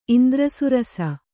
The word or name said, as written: ಇಂದ್ರಸುರಸ